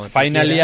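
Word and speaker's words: F1nally: Antes de pasar sí, a Helmut Marko, que Porsche ya ha registrado una marca, Finally, voy a pronunciarlo así, bueno, porque ahí en medio hay un uno. – Sí, es como Finally, hacen el juego de palabras con F1 y Finally.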